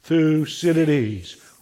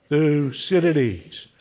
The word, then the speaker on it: Thucydides: Thucydides. One more time. Thucydides.